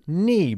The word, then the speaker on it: knee: Knee. – Knee. Bien, con esta K silenciosa.